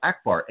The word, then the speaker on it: Ackbar: Okay, it's not pronounced with 30 R's, all right? But it's still Akbar. A-C-K-B-A-R.